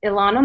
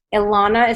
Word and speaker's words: Ilana: My name is Ilana Mulstein. Ilana is spelled with an I.